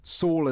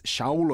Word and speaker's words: Shaul: Saul is how we pronounce it in English, but really it's Shaul of Tarsus.